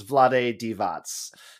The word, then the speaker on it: Vlade Divac: You were much closer on the second take there. Yeah. Okay. That's, I think the closest we can get here, is Vlade Divac.